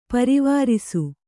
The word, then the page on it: ಪರಿವರಿಸು: parivarisu